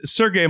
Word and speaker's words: Sergey: So, guys, like, I want to give a little bit of background on how Sergey, am I pronouncing your name properly? – Yes, yes.